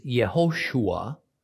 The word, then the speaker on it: Yehoshua: Pronouncing it phonetically, one way is Yehoshua, the other way is Yeshua.